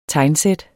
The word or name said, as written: tegnsæt